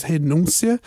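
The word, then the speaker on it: renúncia: Well, it's why in Portuguese it's more similar to renounce, because the pronunciation is renuncia.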